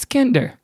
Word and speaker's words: Kinder: And I said, I think it's pronounced kinder. Like a real idiot. And the lady behind the counter was like, oh, no, it's Kinder.